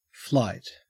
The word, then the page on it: flight: Australian English